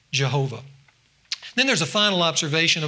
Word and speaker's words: Jehovah: But if you look at the consonants of Yahweh and you add the vowels underneath it of Adonai, you pronounce that mixture of consonants and vowels, Jehovah.